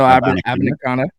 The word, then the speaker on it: Abanikanda: And I'm going to go with Abanikanda, Israel Abanikanda.